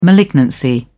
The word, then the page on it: malignancy: British English